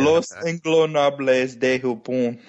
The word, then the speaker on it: Los Ingobernables de Japón: And the actual power struggle card, too, is interesting because the really the last couple months when you'd have Los Ingobernables, oh, man, I so know how to say this, too, Ingobernables. Hook me up. Help me here. – Los Ingobernables de Japón. – Yes, and I can speak Spanish, and I still screwed it up.